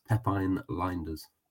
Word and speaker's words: Pepijn Lijnders: And apparently, I uh, I looked it up as well afterwards, and apparently, in Dutch, that is how it's pronounced. It's Pepijn Lijnders.